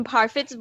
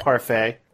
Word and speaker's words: Parfit: The point is, coming from Parfit's point of view, it's both you... – It's pronounced Parfait. – No, it's not. It's not. How dare you? It's not. It's Parfit.